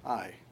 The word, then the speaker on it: Ai: But it actually is pronounced that way, Ai.